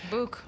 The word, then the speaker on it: Bouc: Well, who is it that the doctor says to him, or Book, how do you say that, B-O-U-C, that character? – Book? Yeah, Book. Let's go with Book.